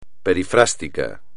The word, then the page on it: perifrástico: espagnol